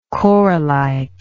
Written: corallite

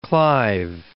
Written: Clive

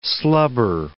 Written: slubber

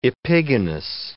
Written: epigonus

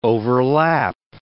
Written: overlap

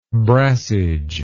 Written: brassage

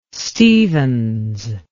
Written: stephens

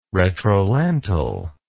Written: retrolental

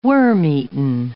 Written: worm-eaten

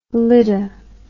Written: lydda

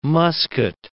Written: musket

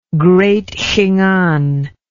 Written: Great Khingan

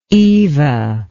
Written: eva

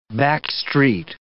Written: backstreet

